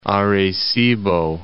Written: Arecibo

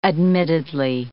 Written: admittedly

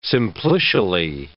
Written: simplicially

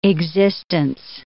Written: existence

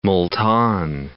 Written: Multan

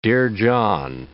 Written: Dear John

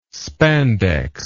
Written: spandex

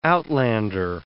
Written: outlander

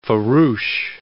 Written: farouche

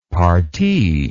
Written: parti

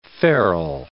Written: ferule

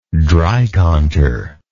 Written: dreikanter

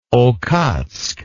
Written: okhotsk

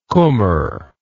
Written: kummer

